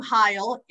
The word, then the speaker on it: Heil: Now, most people who know me, of course, having the last name Heil, it rhymes with smile.